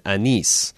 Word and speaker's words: Anis: My wife is Egyptian, and her father, who is actually from Egypt, has the middle name of A-N-I-S, pronounced like you did at one point, Anise.